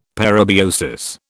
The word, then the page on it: parabiosis: British English